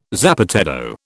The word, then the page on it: zapateado: British English